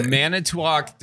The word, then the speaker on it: Manitowoc: Because I want to say like so, Manitowoc. – Yeah, no, Manitowoc, definitely. I think you kind of throw a D in there if you say it fast. Fast. That was very Wisconsin pronunciation.